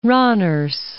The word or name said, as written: Randers